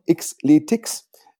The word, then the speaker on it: Xletics: das wird ausgesprochen Xletics wie Extreme Athletics.